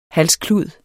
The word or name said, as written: halsklud